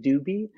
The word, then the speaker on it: Dewbie: It's called the Doobie Stone, and it's spelled D-E-W-B-I-E, Doobie.